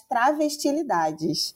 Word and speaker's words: Travestilidades: que se chama Pedagogias das Travestilidades. É, falei certo, Maria Clara, agora? – Falei. Falou sim.